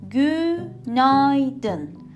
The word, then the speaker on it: günaydın: Günaydın. Günaydın. Three syllables, okay? Günaydın.